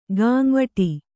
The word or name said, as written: ಗಾಂವಠೀ